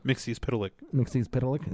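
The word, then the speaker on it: Mxyzptlk: Mixius Pitalik? Is that how you say it? – Yeah, that's how you say it.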